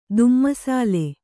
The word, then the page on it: ದುಮ್ಮಿಸಾಲೆ: kannada